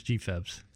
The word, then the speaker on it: GFEBS: Is that pronounced GFEBS, the acronym? – It is commonly referred to as GFEBS.